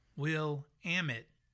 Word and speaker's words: Willamette: So this person says, so for the record, the correct pronunciation of Willamette, as it relates to the Oregon wine region, is Willamette. – Willamette. – As in Willamette, damn it.